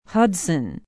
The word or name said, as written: Hudson